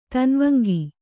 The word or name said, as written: ತನ್ವಂಗಿ